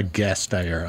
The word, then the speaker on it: Gasteyer: Gasteyer. I don't know if I'm pronouncing her name right. – Yep, the CEO. You are. Yep.